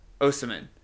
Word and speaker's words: Osimhen: You got to go get, and I'm going to say his last name wrong, Victor Osimhen. It's definitely wrong. Is that right? – I think it's Osimhen. – Osimhen. Victor Osimhen.